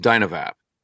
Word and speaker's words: DynaVap: So the name of the company is DynaVape. – DynaVape.